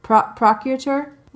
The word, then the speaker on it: procurator: Okay, so she meets Gellis Duncan, who is the procurator. I cannot pronounce that. Procurator. Like proctologist. Oh, procurator.